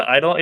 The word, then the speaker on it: Eidolon: Oh, God, how do you pronounce that? The, Eidolon? – The Eidolon, yeah.